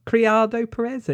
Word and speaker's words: Criado Perez: So basically people have been campaigning to get a woman on there, including Caroline Cardio Perez. Is that how you say it? Criado Perez, anyway, her name.